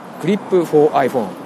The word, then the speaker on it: KLYP4iPhone: KLYP4iPhone5 クリップと読むのかなというふうにご紹介したんですが、やはりこれはクリップ 4iPhone で間違いないようでございました。